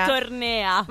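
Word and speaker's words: Övertorneå: che credo si pronuncia Ovortenea. – Ovortornea.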